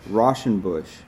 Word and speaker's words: Rauschenbusch: Rauschenbusch.